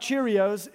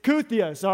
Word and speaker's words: kyrios: It's spelled like kyrios, but it's not cheerios, it's kuthios, all right?